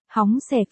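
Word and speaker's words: hóng xẻ (红色): Màu hồng, không phải là pink mà là red, xin thưa rằng, trong tiếng Trung, màu đỏ red. Đọc là, hóng xẻ, phiên âm ra Hán Việt là, hồng sắc, còn, màu hồng, pink, tiếng Trung là, pha nhóng xẻ, Hán Việt là.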